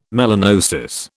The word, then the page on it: melanosis: British English